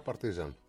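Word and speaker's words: Partizan: Δεν υπάρχει λέξη Σερβία, λέει, που να τονίζει τη λίγουσα. Άρα Παρτίζαν.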